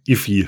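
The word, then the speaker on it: IIFE: Ich hänge immer noch an dieser Aussprache Iffy fest. Ich weiß nicht, wie ich die verpassen konnte. – Ich glaub, aber gibt's doch, oder? Oder bilde ich mir das jetzt ein gerade? – Nee, ist absolut. So spricht man das aus.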